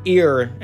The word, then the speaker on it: ere: So I think ear, as I'm pronouncing it, is basically like lest, basically.